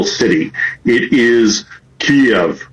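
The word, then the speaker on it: Kyiv: The Ukrainian closest approximation that we can do as non-Slavic speakers is Kiev. So the Y ends at the first syllable.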